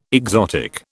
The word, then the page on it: exotic: British English